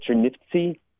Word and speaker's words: Chernivtsi: It's a city, now what's called Chernivtsi. At the time, the Jewish population called it Chernivish, which is the Yiddish pronunciation.